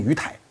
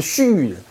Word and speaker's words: 盱眙: 第一个人叫做许建忠，这个许老板大家要记住，江苏盱眙人，这两个字不太好念，很多人念成鱼台啊，是盱眙人，他啊。